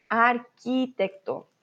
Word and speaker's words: arquitecto: Ojo, Q, U, I, no pronunciamos la U. Arquitecto, no arquitecto, ¿vale?